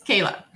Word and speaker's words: Kayla: It's Kayla.